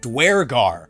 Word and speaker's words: Duergar: Small spoiler, they pronounce it the way that I always did, Duergar.